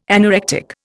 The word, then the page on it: anorectic: Indian